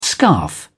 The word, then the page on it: scarf: British English